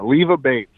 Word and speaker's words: Leva Bates: Bambi Hall taking on Leva Bates. Did I say that right? – Yeah, Leva Bates.